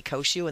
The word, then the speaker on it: Cociu: My name again is Dorothy Koshu, and the last name is spelled C-O-C-I-U.